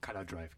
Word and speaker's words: Color Drive: Und zwar ist das die Band Color Drive, aber äh, war jetzt richtig ausgesprochen. Color Drive, genau.